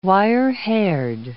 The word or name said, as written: wirehaired